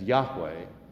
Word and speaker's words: Yahweh: the four letters which we pronounce as Yahweh, the name that God gave Moses to give to the people of Israel when they were slaves in Egypt.